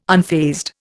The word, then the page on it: unfazed: Indian